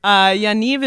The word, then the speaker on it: Yaniv: Yaniv is pronounced like Yaniv, and it rhymes with achieve.